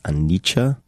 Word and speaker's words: Anicca: I think it's pronounced Anicca, A-N-I-C-C-A, which means impermanence, which in retrospect is kind of hilarious.